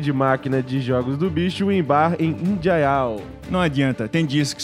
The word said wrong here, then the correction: Indaial: Indaial. – Indaial não, ah, né, foi amigo?